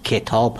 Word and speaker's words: کتاب: به طور مثال تلفظ درست واژه کتاب، کتاب هست، یعنی با کسره کاف تلفظ میشه نه با فتح.